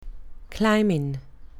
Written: climbing

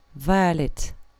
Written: violet

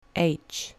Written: h

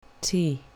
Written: t

